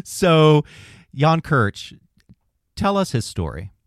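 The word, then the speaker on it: Krč: Kirch. It's spelled K-R-C. It's a Czech name.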